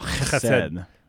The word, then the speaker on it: Chesed: To say it like a cat spitting up a hairball. Chesed.